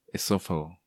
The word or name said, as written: Esofago